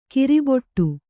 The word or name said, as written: ಕರ್ಬೆಟ್ಟು